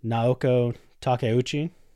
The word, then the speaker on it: Naoko Takeuchi: And he is also married to, tell me if my pronunciation is wrong with this, Naoko Takeuchi.